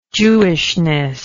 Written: jewishness

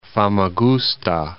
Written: Famagusta

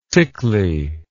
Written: tickly

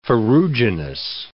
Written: ferruginous

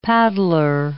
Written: paddler

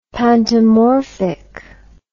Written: pantomorphic

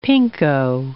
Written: pinko

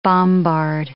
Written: bombard

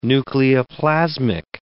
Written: nucleoplasmic

